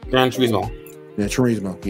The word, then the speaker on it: Gran Turismo: Anybody check out the Grand Tomorrow trailer? – It looks interesting. – I think I said that right. – Gran Turismo. – Yeah. – Yeah, my bad.